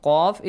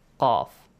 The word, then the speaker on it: qaf: So, the next one is again the letter dhal, the letter qaf is very important, okay? Qa, qa. Sometimes people make mistake and they pronounce it the same as the letter kaf. Yes? – Yeah. – Kaf, qaf.